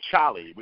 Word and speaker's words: Cholly: he gave me the name Cholly, which is spelled C-H-O-L-L-Y.